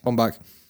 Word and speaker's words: Baumbach: Who represented Scarlett, and the director is Noah, I don't know how to pronounce his second name. – Bombach.